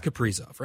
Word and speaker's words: Kaprizov: Kaprizov. Is it? It's Kaprizov, right? – It is. It's Kirill Kaprizov.